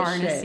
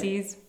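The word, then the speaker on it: Pharnaces: I just don't know how to pronounce any of this Farnaces. – Shit. – Farnaces. – Farnaces. – It's kind of a funny name. – Farnaces? It's like furnaces, but totally with an accent.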